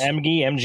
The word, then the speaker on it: Emge: So, starring David Emge, is that how you say it? – Sure, yes. Emge as Stephen Andrews, a.k.a.